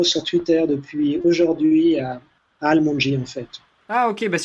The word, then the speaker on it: ALMONDGY: Almondji, hein, pour ceux qui nous écoutent, ça s'écrit A-L-M-O-N-D-G-Y.